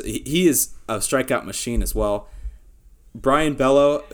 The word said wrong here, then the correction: Bello: Bayo, I butchered it's Bayo. – That. – I had to look that up. It's Brian Bayo.